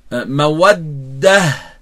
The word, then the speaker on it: مودة: كذلك الصديقين لا يقولن وكذلك تركز على الحرف المشدد عندما تقرأه تنطق هكذا مودة مودة لأن الحرف المشدد عبارة عن حرفين فنأتي به ساكنا ثم متحركا.